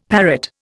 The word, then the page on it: parrot: Indian